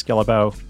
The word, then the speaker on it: Guillebeau: I'm also sharing every day on social media under my name, Chris Guillebeau. That's G-U-I-L-L-E-B-E-A-U.